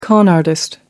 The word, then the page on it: con artist: American English